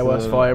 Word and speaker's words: Westfalia: Yeah, Westfalia.